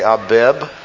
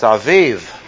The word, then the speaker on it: Aviv: You'd say Abib. You might say Abib. But neither are correct because it's simply a base, sounded like a V, and it's Aviv, not Abib or Abib.